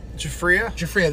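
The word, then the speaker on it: Giuffria: How do you say the Jufria? – Jufria, that's how you say it.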